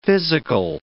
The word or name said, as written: physical